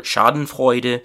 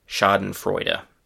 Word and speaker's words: Schadenfreude: So the German pronunciation is very similar, Schadenfreude, and in English it is Schadenfreude.